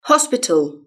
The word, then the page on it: hospital: British English